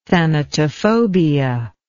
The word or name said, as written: thanatophobia